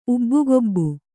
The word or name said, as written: ಉಬ್ಬುಗೊಬ್ಬು